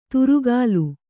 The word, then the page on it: ತುರುಗಲು: turugalu